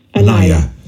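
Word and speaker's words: Anaia: She runs, did I pronounce that right, Pina? – Anaya. – Yeah. It's A-N-N-A-I, am I right? – It's A-N-A-I-A.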